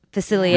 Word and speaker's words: Facilier: I think it's Facilier. I think it's Frenchie. – Facilier. Yeah. Okay. Yeah. All right. Then I am saying it right, but I always get like nervous.